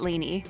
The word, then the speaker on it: Leni: She pronounces it Leni. – Leni. – It's spelled okay. – L-E-N-I.